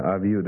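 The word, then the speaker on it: Aviu (אביו): But this is one of the reasons why some of the books teach you to pronounce a final wow as a V sound. And that makes it easier to pronounce something like this. Instead of saying Aviu, it would be Aviv is easier.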